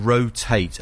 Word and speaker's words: Rotait: The two of them go together, you know, acoustic guitar and cello, beautifully played, and they're called Rotate, only it's spelled R-O-T-A-I-T.